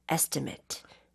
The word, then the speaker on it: estimate: 여러분, 이 estimate 발음은 조심하셔야 돼요. 왜냐하면 동사로 쓸 때는 estimate라고 right. – 하지만 여기서는 명사예요. 그래서 estimate. Matched the estimate.